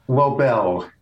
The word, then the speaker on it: Lobel: Jules Lobel, L-O-B-E-L. – Rhymes with Nobel.